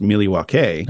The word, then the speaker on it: Milwaukee: And you know what's interesting is Milwaukee is actually an old Algonquin word pronounced Milwaukee.